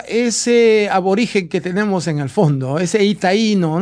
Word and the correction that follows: Taíno: ¿Cómo se dice itaíno, no? O taíno.